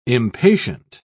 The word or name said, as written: impatient